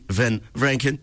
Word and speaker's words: Van Vranken: Van Vranken.